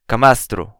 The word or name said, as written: camastro